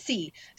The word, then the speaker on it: Sey: Sayinabo Say. No, she goes C. It's C. Sayinabo C. The last name is spelled S-E-Y. Everyone says Say. I've watched several interviews with her, and everyone says her name wrong.